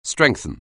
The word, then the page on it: strengthen: anglais